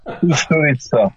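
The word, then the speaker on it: Kusturica: امیر کاستاریکا، آخر من تلفظ درستش رو نفهمیدم، فیلم بابا به مأموریت رفته است تو جشنواره نمایش داده کوستوریتسا.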